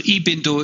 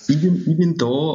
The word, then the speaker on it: Ibindo: Also Ibindo ist die korrekte, also das e auf der ersten Silbe werde ich es in Zukunft betonen, das ja, ist sehr wichtig, ja klar. – Ibindo und ich,